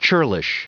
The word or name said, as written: churlish